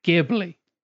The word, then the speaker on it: Ghibli: So yeah, with the little intros out of the way, this week's topic is Studio, and I'm going to say it, Ghibli. With a soft G or a hard G, whichever G that is. That's a hard G with the G.